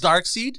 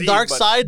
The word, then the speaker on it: Darkseid: Some people say Darkseid. Some people say Darkseid. – He has three names. He has it's Darkseid, Darkseid, and the seed is written two different ways.